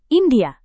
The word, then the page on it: india: indonesian